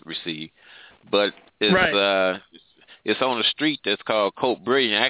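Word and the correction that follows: Côte Brillante: Actually, it's pronounced Cote Brillante, which means brilliant hill when it's translated.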